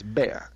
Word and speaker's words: Bexar: I actually said is this Bexar? She goes, oh, yeah, it does have an X, but it's pronounced bear.